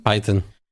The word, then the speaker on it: Python: En el 2013, güey, en Florida, se creó el ¿cómo se pronuncia en inglés? Python, güey. ¿Python? – Python.